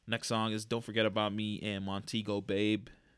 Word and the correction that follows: Montego Bay: or Montego Bay. That's how you say it.